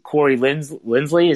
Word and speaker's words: Corey Linsley: And if that's the only move they make, if they bring in, and not even like a Corey Linsley, is that how you say it? – Yeah, that's right.